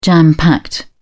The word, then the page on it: jam-packed: British English